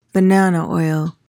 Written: banana oil

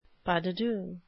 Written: pas de deux

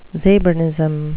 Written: zabernism

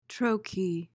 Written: troche

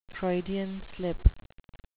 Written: Freudian slip

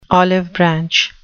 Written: olive branch

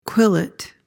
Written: quillet

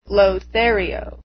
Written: lothario

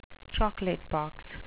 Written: chocolate-box

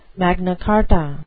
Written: Magna Carta

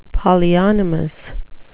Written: polyonymous

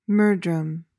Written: murdrum

